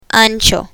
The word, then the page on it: ancho: spanish